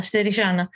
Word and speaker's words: Cerrillana: But I do know that the songs are Piel de Rancho, and La Cerrillana, I should say it like the Argentines, La Cerrillana by Los Chalchaleros.